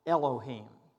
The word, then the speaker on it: Elohim: And I'm going to pronounce it and spell it so you can write it down. Elohim. E-L-O-H-I-M. Elohim. E-L-O-H-I-M.